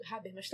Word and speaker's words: Habermas: O Baum ele comenta, tá nessa coisa de decadência do público, né, que é uma, uma vibe que o Habermas também entra, eu falei certo, Habermas também Isso.